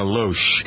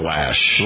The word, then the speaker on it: Loesch: You have somebody new working with you, Richie, called Dana Loesch. – Dana Lash. – Lash. Mm-hmm. I thought it was Loesch. How does she spell it? – It's spelled Loesch. It's spelled L-O-E, but it's pronounced Lash.